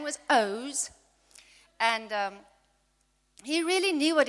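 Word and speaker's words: Oğuz: His name was Oz, but you spell it O-G-U-Z.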